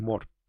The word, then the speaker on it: Moore: Bueno, los gringos lo pronuncian Moore. No sé por qué a veces es Moore y a veces es Moore. Pero bueno, es Alan Moore. – Es por el inglés británico.